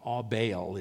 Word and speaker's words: Abel: Abel is how it's pronounced in the original Hebrew.